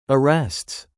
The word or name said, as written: arrests